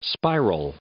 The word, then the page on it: spiral: noun